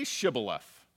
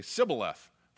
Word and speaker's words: Shibboleth: If he said no, then they would say to him, Say Shibboleth. But they would say, Sibboleth, for they could not pronounce it correctly.